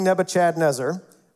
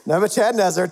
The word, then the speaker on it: Nebuchadnezzar: Daniel 4 verse 28 says this, all this happened to King Nebuchadnezzar, that's how you pronounce it. I expect to hear that in the future. That is how you say it. Nebuchadnezzar,